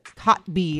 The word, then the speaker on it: Kotb: Hoda Kotb is how you say her last name.